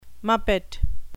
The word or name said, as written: muppet